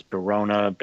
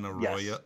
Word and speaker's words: Benaroya: That's from, I'm going to say it wrong, I'm trying to remember what it is, Barona, or something like that, out in Seattle.